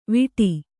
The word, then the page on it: ವಿಟಿ: viṭi